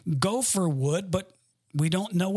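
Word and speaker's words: gopher (גֹּפֶר): The actual Hebrew word, believe it or not, is gopher. That's how you pronounce it.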